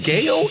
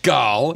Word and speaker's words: Gal: You said Gail Gadot. – Let's say it with me again. – It's Gal.